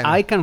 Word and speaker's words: Icahn: Se, se Icon fosse scritto con la I minuscola e la C maiuscola, sarebbe chiaro che si legge Icon. – Ah, è vero, hai ragione. Icon. Carl Icahn.